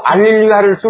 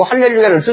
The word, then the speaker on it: 할렐루야: 천주교에서는 알렐루야 쓰고요, 개신교에서는 할렐루야 써요. 이것은요, 어떤 사본에는 수음표가, 역심표는 히자 발음으로 오고, 그냥 우리 일반적인 수음표는 이렇게, 아 발음이거든요. 그래서 이제 그 사본에 의해서 알렐루야를 쓸 수도 있고, 할렐루야를 쓸 수가 있어요.